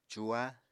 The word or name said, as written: cuha